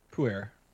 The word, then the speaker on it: Pu'er: Pu'er.